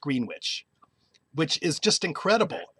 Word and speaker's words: Greenwich: But there are three Greenwich townships in New Jersey, pronounced Greenwich.